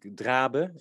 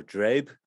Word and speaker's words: Drabe: Patrick Drabe is my name, or Drabe, if you have to pronounce it in English.